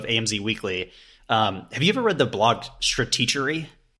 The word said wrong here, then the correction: Stratechery: It's uh, Stratechery. – That it is. I always say it wrong.